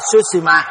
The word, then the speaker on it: Sushima: Người con lớn của vua A Dục là Sushima, mình đọc là Sushima nhưng mà Ấn Độ nó không đọc Sushima, nó đọc Sushim.